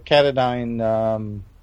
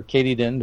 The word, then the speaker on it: Katadyn: I've got a Katadyn or Katydyn, depending on how you pronounce it, gravity system as well, like a Basecamp gravity system.